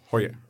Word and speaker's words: Heuer: Nu vill jag ha en Tag Heuer eller vad den kan uttalas. – Hoje.